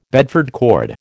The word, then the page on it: Bedford cord: American English